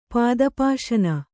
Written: ಪಾದಪಾಶನ

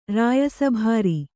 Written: ರಾಯಸಭಾರಿ

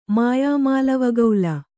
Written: ಮಾಯಾಮಾಲವಗೌಲ

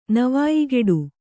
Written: ನವಾಯಿಗೆಡು

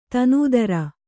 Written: ದೀನ್‍ದಾರ್

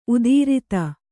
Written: ಉತ್ತರಾಧಿ

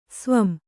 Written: ಸ್ವಂ

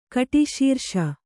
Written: ಕಟಿಶೀರ್ಷ

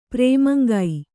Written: ಪ್ರೇಮಂಗೈ

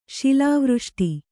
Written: ಶಿಲಾವೃಷ್ಟಿ

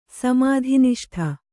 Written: ಸಮಾಧಿನಿಷ್ಠ

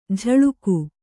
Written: ಝಳುಕು

